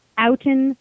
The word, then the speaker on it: Oughton: which is Oughton Limited, right? – Correct.